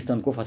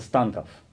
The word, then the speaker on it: Станков: Родился в Нижнем Тагиле, даже получается ленить не Станков, а Станков, ударение на первом слуге, болгарин.